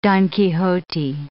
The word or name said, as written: Don Quixote